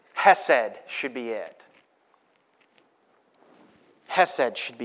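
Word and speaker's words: chesed (חסד): The Hebrew word for it is chesed. Go ahead, say chesed. You don't have to say it like that. Chesed.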